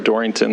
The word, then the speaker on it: Dorrington: For the record, my name is Greg Dorrington. That's spelled D-O-R-R-I-N-G-T-O-N, here today on behalf of the Montana Medical Association, standing in support of HB 121.